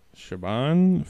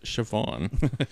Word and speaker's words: Siobhan: Siobhan Fallon Hogan, I believe is how you say her name. Yes. Name is pronounced Siobhan.